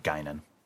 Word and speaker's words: Guinan: It's pronounced like Whoopi Goldberg's bartender on TNG, Guinan.